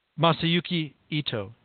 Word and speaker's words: Masayuki Ito: I think I'm saying his name right. Am I saying his name right? – Masayuki Ito, yes. – Masayuki Ito.